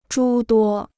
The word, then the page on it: 诸多: zhū duō